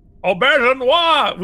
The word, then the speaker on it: Auberjonois: Auberjonois.